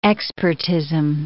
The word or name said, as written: expertism